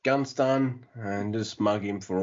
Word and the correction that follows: Gunston: You don't ever say that right. You know, it's Gunston. That's how you're supposed to say it.